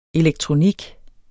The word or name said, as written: elektronik